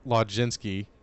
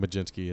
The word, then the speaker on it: Mlodzinski: And then Carmen Carmen Mlodzinski, Majinski, I think Majinski is how you pronounce that.